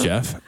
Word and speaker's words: Geoff: Even though it's spelled G-E-O-F-F, it's Jeff.